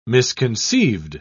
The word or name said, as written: misconceived